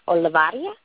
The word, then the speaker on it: Olivarria: My name's Crystal, and my last name's Olivaria, O-L-I-V-A-R-R-I-A. And I spend a lot of time interacting on Google Plus. – Well, you say your last name so much better than those of us on the East Coast. – The trick is to roll your tongue with the double R's.